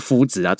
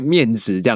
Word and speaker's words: 麸质: 那怎么念也是应该念麦质或者是麸质啊，怎么会念面质这样子啊？